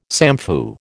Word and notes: samfu: American English

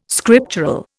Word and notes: scriptural: Indian

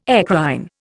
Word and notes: eccrine: Indian

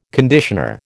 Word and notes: conditioner: American English

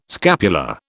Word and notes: scapular: British English

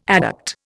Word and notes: adduct: Indian

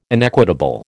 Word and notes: inequitable: American English

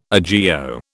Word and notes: agio: British English

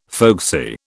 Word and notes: folksy: British English

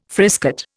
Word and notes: frisket: Indian

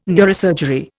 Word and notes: neurosurgery: Indian

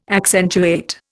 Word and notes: accentuate: Indian

